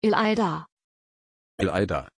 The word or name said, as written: ilayda